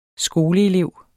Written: skoleelev

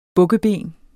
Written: bukkeben

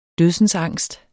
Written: dødsensangst

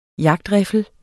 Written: jagtriffel

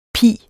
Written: pis

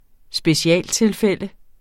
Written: specialtilfælde

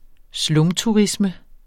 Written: slumturisme

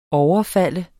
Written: overfalde

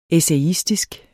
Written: essayistisk

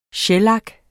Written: shellak

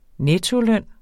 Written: nettoløn

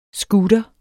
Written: scooter

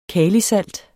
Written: kalisalt

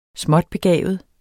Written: småtbegavet

